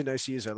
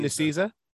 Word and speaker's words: Nicieza: So I'm pretty sure it's pronounced Fabian Nicieza. – Nicieza. Okay. I had no idea how to say it. All right. Sorry, continue. – We could be wrong, but I'm pretty sure it's Nicieza.